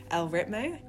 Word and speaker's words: El Ritmo: This week's guest is Courtney Waters, who is one of our own at URN with her show El Ritmo. Did I say that right? – Yeah, that's okay. Correct.